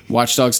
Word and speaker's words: Watch Dogs: And then finally, they revealed a whole ton of gameplay for Flop Dogs 2. I said that wrong. Watch Dogs 2.